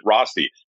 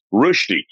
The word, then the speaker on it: Rösti: Now, you pronounced it like any Westerner should pronounce it, Rösti. But the two umlauts over the O, actually from Switzerland, you would pronounce it, or from Europe, Rösti. Rösti, you got to roll that tongue.